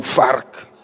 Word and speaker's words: vark: In Afrikaans, it's easy, you vark. With the emphasis on the R and the K. Vark.